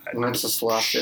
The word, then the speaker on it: Wenceslas: Did I pronounce that right? – Wenceslas, it's close enough.